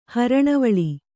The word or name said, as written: ಹರಣವಳಿ